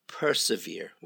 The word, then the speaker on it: persevere: Now, this one is, just be careful of the pronunciation, to persevere. Yeah? Persevere. When something is severe, it's difficult.